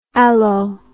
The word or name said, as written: allo